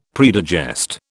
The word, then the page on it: predigest: British English